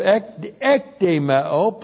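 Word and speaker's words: Ekdemeo (ἐκδημέω): E-K-D long E-M-E long O. Ekdemeo.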